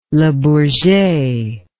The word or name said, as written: le bourget